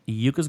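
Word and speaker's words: Iuka's: It is spelled I UKA, Yuka's Grog.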